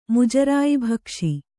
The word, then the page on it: ಮುಜರಾಯಿಭಕ್ಷಿ: Kannada